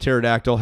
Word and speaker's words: pterodactyl: how to not pronounce the P in pterodactyl, how to pronounce Anglicanism, how to pronounce most words in the English language.